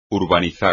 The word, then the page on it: urbanizar: espagnol